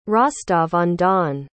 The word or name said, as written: Rostov-on-Don